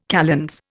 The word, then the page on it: kalends: Indian